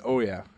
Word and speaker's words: Ouya: I've heard it pronounced Ouya and Oya.